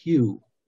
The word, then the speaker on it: HU: Another syllable that can be used, or another mantra, is the word HU, which is spelled H-U, just pronounced like the color or the hue of, a color or the guy's name, Hugh.